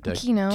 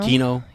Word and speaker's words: Kino: They're like, when Lino, is that how you say his name? The leader, I forget his name. But Kino. – Kino, right? Yeah.